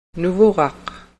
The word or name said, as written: nuvuraq